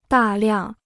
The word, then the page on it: 大量: dà liàng